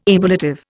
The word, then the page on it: ablative: Indian